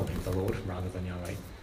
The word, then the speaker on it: Yahweh: So you kind of have to, we've kind of guessed really at the pronunciation Yahweh, which is why you have Jehovah's Witnesses. Jehovah was an old guess at how you pronounce God's name.